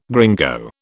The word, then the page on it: gringo: British English